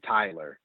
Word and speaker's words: Tyler: It's hard to not pronounce it right. It's a very basic name. The only times it's either Tyler or Taylor. Tyler.